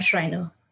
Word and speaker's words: Schreiner: Join me in welcoming Cam Schreiner.